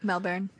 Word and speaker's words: Melbourne: I don't even know how to say that right, but Melbourne. – Yeah, there you go. – You said it better than most people.